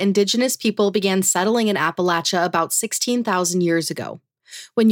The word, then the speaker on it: Appalachia: And I've got more Appalachia. – It's Appalachia. Say it right.